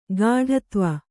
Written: ಕೆಡೆತಿವಿ

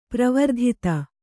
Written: ಪರಿವರ್ಧಿತ

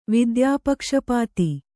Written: ವಿದ್ಯಾಪಕ್ಷಪಾತಿ

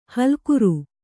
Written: ಹುಲ್ಲೆಕರು

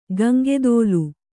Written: ಗಂಗೆದೋಲು

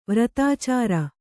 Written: ವಾರ್ತಾಚರ

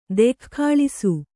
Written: ದೆಖ್ಖಾಳಿಸು

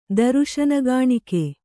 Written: ದರುಶನಗಾಣಿಕೆ

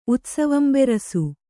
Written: ಉತ್ಸವಂಬೆರಸು

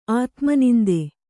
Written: ಆತ್ಮನಿಂದೆ

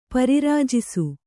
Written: ಪರಿರಾಜಿಸು

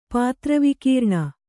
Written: ಪಾತ್ರವಿಕೀರ್ಣ

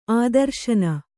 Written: ಆದರ್ಶನ

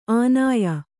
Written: ಆನಯ

